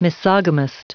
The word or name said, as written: misogamist